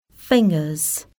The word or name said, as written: fingers